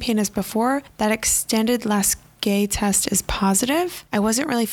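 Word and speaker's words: Lasègue: The next test I have been pronouncing wrong. my entire career. It's pronounced the Lasague test. It's L-A-S-E-G-U-E. I've been pronouncing Lasague.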